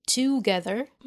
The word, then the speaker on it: together: وكلمة together ناخد بالنا ان ال O في together ما بننطقهاش O, بننطقها أه بدون ضم الشفتين together. وبرضو ال ER ما بننطقهاش R, بنقولها R, together, together.